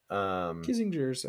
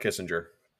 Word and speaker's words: Kinzinger: Kinzinger's okay. – Kinzinger, not Kissinger. Those are very different people. – I'd blame being tired, but we both know I just mispronounced it.